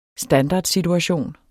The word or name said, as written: standardsituation